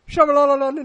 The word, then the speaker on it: Shyamalan: Night, however you say his name, Shyamalan. – Shyamalan, and Ding Dong.